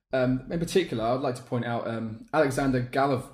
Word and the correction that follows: Golovin: I'm going to try and pronounce his name correctly. Alexander Golovin.